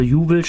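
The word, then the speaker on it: Uwell: Also Juwel schreibt man U, W wie Wilhelm, E wie Emil, e Doppel L wie Ludwig.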